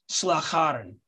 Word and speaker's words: Slagharen: It's Slagharen, and it's pronounced Slagharen, Slagharen. And I contact, because they were doing dynamic pricing on their website.